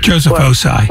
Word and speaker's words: Joseph Ossai: Joseph Ossai.